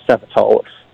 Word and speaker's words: Sapatolos: Well, it starts with a T, so you got to work it out pretty quickly. There's no T. You don't pronounce the T, so Sapatolos.